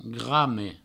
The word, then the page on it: grame: Provençal